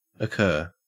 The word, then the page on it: occur: Australian English